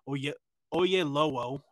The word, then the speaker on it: Oyelowo: And then we got David Oyelowo, I believe is how it's pronounced.